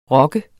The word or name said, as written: rokket